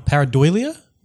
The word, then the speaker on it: pareidolia: So it's the phenomenon of pareidolia. Is that how you say it? Does anyone know how to actually say that? – That's how I would say it.